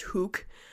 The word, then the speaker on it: Hook: It's located on the Hook, or it's actually pronounced Hook, Peninsula in County Wexford, Ireland.